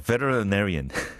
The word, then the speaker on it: veterinarian: Kind of a literary보다 한 몇 배 어려운 건데 veterinarian 수의사 veterinarian 정말 힘들죠. And again, if you pronounce it the British way, it's much easier. But it's those American, you know, pronunciation that you know treats T as L, veterinarian.